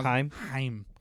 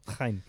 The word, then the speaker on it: Chaim: His name is spelled like Chaim, but it's pronounced Heim. – Heim.